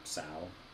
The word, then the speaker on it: sow: like, but also S-O-W, the word so, or sow, I think it's pronounced sow actually, means to like reforest or replant.